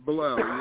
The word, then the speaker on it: Bilal: This is Bilal.